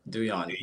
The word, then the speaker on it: Douyon: Yep, Douyon.